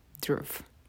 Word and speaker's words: Dhruv: Dhruv, the way it's spelled, D is in David, H, Henry, R, Rose, U, Umbrella, V, Victor, Dhruv.